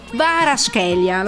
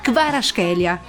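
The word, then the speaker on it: Kvaratskhelia: proprio così, odio Napoli e eh, Kvaraschelia, l'ho detto giusto? Eh, bravo. – Che Giorgiano lui. Va Kvaraschelia.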